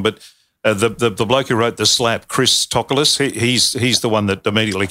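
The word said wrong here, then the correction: Christos Tsiolkas: Yeah, Christos Chokas.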